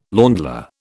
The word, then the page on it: ländler: British English